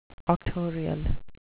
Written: auctorial

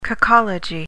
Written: cacology

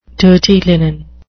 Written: dirty linen

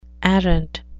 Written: arrant

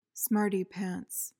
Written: smarty-pants

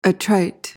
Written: attrite

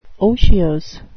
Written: otiose

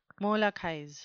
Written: molochize

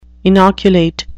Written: inoculate

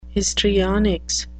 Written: histrionics

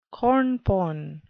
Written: cornpone

